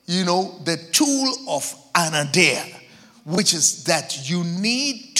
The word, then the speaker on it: anaideia: Yes, it's spelled A-N-A-I-D-E-I-A. Anadeia.